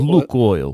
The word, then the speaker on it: Lukoil: And he was also the CEO of Lukoil, I don't know how to pronounce it, L-U-K. – It's Lukoil.